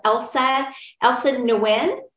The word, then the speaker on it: Elsa Nguyen: I am super excited about today's guest, Elsa Nguyen.